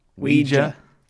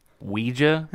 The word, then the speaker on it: Ouija: Ouija. – Ouija. – That's how he says it. – That's how it's pronounced.